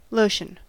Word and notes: lotion: American English

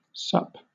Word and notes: sup: British English

